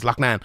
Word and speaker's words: Loughnane: I'm going to talk about the main event first, and then I'll come back to Brendan Lochnan, as I call him correctly, because that's how you say his name, is Lochnan.